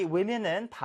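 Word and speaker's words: women: 그 다음, woman 할때 여기는 woman이라고 해야 될까? 그게 아니라 women이라고 발음이 된다. 특히나 이 women은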